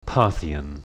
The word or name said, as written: Parthian